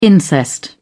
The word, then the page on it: incest: British English